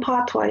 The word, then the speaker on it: Patois: Do you also speak Patois? Am I saying that right? – Yes, Jamaican Patois, yes.